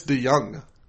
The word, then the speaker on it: DeJong: Chase DeJong is around. – Apparently it's DeJong. – Oh, okay. That's fine. Yeah, I think that's actually the Dutch pronunciation.